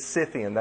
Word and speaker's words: Scythian: My daughter corrected me on the pronunciation of that last week. But it's Scythian. That's the actual pronunciation.